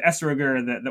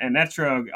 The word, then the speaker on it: etrog: So the esroger, the one who sells an etrog, also called an esrog in Ashkenazi pronunciation.